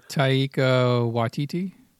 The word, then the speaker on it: Taika Waititi: help me out with the name Tiki. – Oh, Taika Waititi.